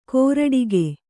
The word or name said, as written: ಕೊರಟಿಗೆ